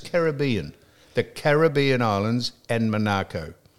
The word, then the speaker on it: Monaco: And I said, how do you pronounce he said, it's Monaco. It's an English, that's, and I said, well, why do people say Monaco?